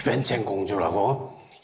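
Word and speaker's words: 웬챙공주라고 (文成): 그 사람을, 그 여자를 이제 불러가지고 운성공주라고 글을 문자 열성자 해가지고 운성공주, 중국 발음으로는 웬챙공주라고 이 사람을 이제 보내기로 택했어요.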